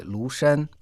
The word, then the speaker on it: 庐山: А в нашей сегодняшней передаче мы совершим виртуальное путешествие в горы Лушан. Лу – это произносится во втором тоне, а Шан – в первом тоне.